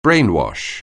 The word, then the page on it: brainwash: anglais